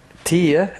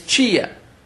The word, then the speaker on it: tia: It could be ant, A-U-N-T. – In Portuguese? – Tia, that's the way they pronounce in São Paulo. – It's chia.